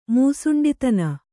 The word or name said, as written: ಮುಸುಂಡಿತನ